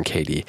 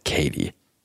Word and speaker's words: Koethe: But if you would like to look up John Cady, his work is really interesting and worth checking out. His name is spelled K-O-E-T-H-E, John Cady.